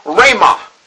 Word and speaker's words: rhema: it is the Greek word rhema. And it's spelled R-H-E-M-A, rhema.